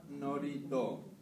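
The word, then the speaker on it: norito (祝詞): Dice, en el sintoísmo, el término oración se pronuncia norito.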